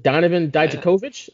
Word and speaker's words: Dijakovic: But in the meantime, we got Keith Lee coming out here because he's going to go against, I should say, Donovan Dijakovic. Is that how you say it now? – Yeah.